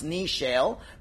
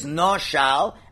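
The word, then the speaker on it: Noshal (נשל): According to the sages, there's no choice but to expound the word the way it should be pronounced, the way God said, Nun Shin Lamed, even though that should not, it should pronounce Nishel, but God said, I want you to pronounce it as Noshal, as an intransitive verb, which refers to the axe itself.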